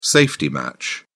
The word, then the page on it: safety match: British English